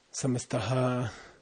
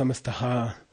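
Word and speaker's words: samastaḥ: They don't understand Sanskrit and there's a final H fricative in Sanskrit. It's like, how do you say that? Loka, samasta, sukino, you know, lokaha. Samastaha, it's a final H. It's a ch. You're supposed to go samastah. You're not supposed to say samastaha, and then it messes up the meter, and they don't notice.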